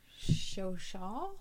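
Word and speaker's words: Chauchat: The one that I would crush would be the Shoshaw? – Yes, that's how it's pronounced. – Yeah, you would not think that's how it's pronounced by the way that it's spelled.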